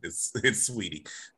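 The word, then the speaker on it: Saweetie: She says the A is silent though, Chris, just so you know, she spells it differently just to be unique, but the A is silent. It's sweetie.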